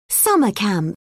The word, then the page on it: summer camp: British English